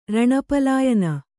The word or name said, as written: ರಣಪಲಾಯನ